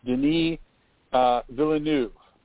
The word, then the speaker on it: Denis Villeneuve: I think the big surprise here was, and Kit, I actually looked up this pronunciation ahead of time, Denis Villeneuve. That's the correct pronunciation.